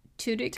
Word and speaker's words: Tudyk: Alan Tudyk? – Tudyk.